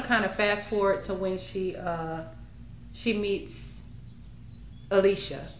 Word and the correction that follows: Alicia: Isn't that how you... – It's Elijah, but in the book, y'all, it is spelled Alicia. Had I not kind of gone back and listened to some other excerpts, I would have been saying Alicia too and would have been highly confused. But it's actually Elijah.